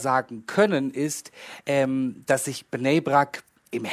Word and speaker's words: Bnei Brak: Und dieser Anschlag gestern Abend, am Dienstagabend, der hat stattgefunden in Bnei Brak. Korrigier mich bitte, wenn ich das falsch ausspreche. – In Bnei Brak, genau.